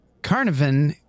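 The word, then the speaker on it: Caernarfon: So shop worker Rhiannon Evans, 25, of Mountain Street in Carnarvon, Gwynedd, pleaded guilty. You don't even want to know how that's spelled, but that's how it's pronounced.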